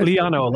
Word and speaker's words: Liano: Liano, like piano.